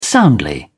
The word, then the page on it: soundly: British English